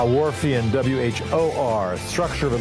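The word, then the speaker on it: Whorfian: The Warpian hypothesis. – Nope, not. Thank you for it. How do you spell that? – It's Wharfian hypothesis.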